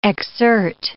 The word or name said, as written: exsert